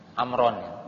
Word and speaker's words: Amr: Jadi kalau Umar tidak memakai wawu ujungnya, jadi tidak memakai tambahan huruf wawu, kalau dibacanya, kalau ada wawu maka dibacanya Amr.